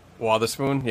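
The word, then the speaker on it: Wotherspoon: Watherspoon, yeah.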